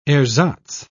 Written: ersatz

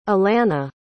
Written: allana

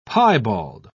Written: piebald

